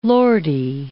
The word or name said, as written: Lordy